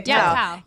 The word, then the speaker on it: Tao: So we did Tao. I always say that. Am I saying that right? Right? – Okay, Tao. Yeah.